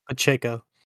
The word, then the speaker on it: Pacheco: How do we say that these days? – Pacheco. – Yeah, Pacheco, that guy.